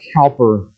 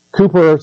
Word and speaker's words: Cowper: Do you know this hymn tune by William Cowper is the way you pronounce his name. It looks like Cowper.